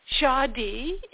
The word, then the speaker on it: Dziady: But Jadi is spelled D-Z-I-A-D-Y. So Jadi is a Slavic pre-Christian practice celebrating their ancestors.